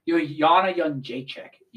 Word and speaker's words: Joanna Jędrzejczyk: Joanna Jędrzejczyk, that's how you say it.